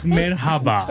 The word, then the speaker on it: Merhaba: Mahaba. – it's Merhaba. – Merhaba, oh, sorry.